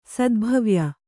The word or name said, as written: ಸದ್ಭವ್ಯ